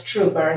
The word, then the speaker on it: Trooper: It's Trooper is my last name. Rhymes with Grouper, if you know what I mean. – Like the fish? – That's right. It rhymes with that.